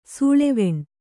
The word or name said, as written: ಸೂಳೆವೆಣ್